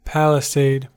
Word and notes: palisade: American English

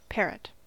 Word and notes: parrot: American English

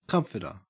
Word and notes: comforter: Australian English

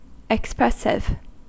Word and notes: expressive: American English

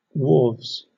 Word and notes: wharves: British English

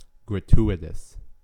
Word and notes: gratuitous: American English

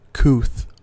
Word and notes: couth: American English

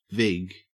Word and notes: vig: Australian English

